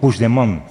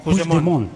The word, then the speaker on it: Puigdemont: Puigdemont. – Puigdemont. Vale. Puigdemont. – Eso es. – Sí, lo dices como francés, así, tiene Puigdemont, como Marbella.